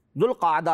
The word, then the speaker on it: ذوالقعدہ: جس کا درست تلفظ ذوالقعدہ ہے۔